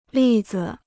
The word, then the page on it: 例子: lì zi